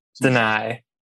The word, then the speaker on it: Dani: Did I do it right? Did I get it right? – Deny.